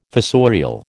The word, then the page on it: fossorial: American English